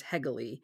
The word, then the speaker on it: Hegele: And I think in the podcast that'll follow this one, I pronounced their name incorrectly, so it is Hegele.